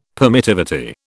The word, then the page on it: permittivity: British English